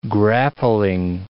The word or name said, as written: grappling